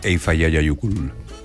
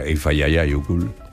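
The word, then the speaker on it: Eyjafjallajökull: Y entonces pues, miré cómo se pronunciaba y creo recordar que era Eifayayayukul.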